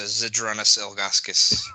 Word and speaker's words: Zydrunas Ilgauskas: No, it's a Zydrunas Ilgauskas Cage. – Yeah. Cage. – I think that's how you say his name. – Yeah, it is.